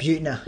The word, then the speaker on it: Buettner: But pretty much the brief of it is that Dan Buettler, how do you pronounce his name? – I think it's Buettner, isn't it? – Buettner. – Yeah, Buettner, that's it, yep.